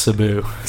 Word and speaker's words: Sabu: I believe it's pronounced Sabu. – Sabu.